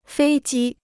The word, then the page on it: 飞机: fēi jī